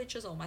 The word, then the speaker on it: Chisholm: It's C-H-I-S-H-O-L-M. Shirley Chisholm, I think.